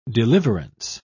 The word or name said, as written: deliverance